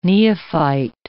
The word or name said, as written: neophyte